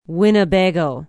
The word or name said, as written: winnebago